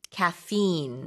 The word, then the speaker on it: caffeine: 이것도 영어 발음 좀 다른데 우리는 카페인, 영어로는 caffeine.